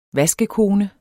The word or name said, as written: vaskekone